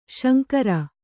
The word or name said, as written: ಶಂಕರಿ